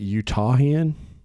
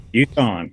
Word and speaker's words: Utahn: is it Utahian? Is that how you say it? – Utahn, yeah.